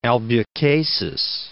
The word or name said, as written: Albucasis